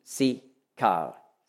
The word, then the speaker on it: Sichar: Merkt man, da sind die gleichen Konsonanten drin wie Sikar. Sichar. Ja? Im Griechischen spricht man K aus.